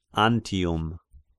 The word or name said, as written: Antium